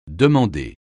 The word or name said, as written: demander